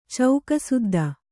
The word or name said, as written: ಚೌಕಸುದ್ದ